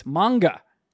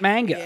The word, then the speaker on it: manga: Also, the way Ephraim pronounced manga is shameful. It's manga, like Pokemanga. Not manga.